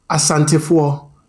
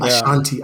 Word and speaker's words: Asante: Like, you know, one of our biggest, and this is off topic, but this is one of my biggest pet peeves, is Asantefo saying Ashanti. I'm Ashanti. I'm Ashanti. The region is called the Ashanti region. What is Ashanti? It is the British inability to pronounce Asante.